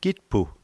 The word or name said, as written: kitpu